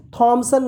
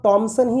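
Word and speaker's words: Thomson: लगेगा तब आप कभी भी थॉमसन नहीं बोलेंगे तब आप टॉमसन ही बोलेंगे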